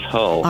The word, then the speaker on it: Hull: Hey, Jason, am I mispronouncing your last name, first of all? – No, you are correct. It is Hull.